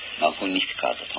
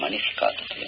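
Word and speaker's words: Magnificat: それから、 176 番はそのまま、このラテン語マグニフィカートと読むんですけども、ここではさっきも言いましたようにマニフィカートと言いましていますが、マニフィカートアニマミア、これはラテン語のここのですね本文のままを下に入れてあります。マニフィカート、そして、カタカナで打って、その長らくですねとラテン語で歌われてきたそのままの言葉も私たちが分かるようにということで、ラテン語のまま書いてあります。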